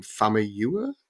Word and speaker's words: Famuyiwa: I always mispronounce his last name. Famuyiwa. Am I saying that right? Okay, I got some nods.